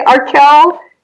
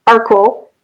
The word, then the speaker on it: Arkell: That one seems easier to me, but apparently Arkell is wrong. It's Arkell. And Gormally, which is how it looks to me, is Gormally.